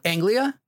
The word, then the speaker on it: Anglia: Is it Angelina? How do you say this? – Anglia? – Let's go with Anglia.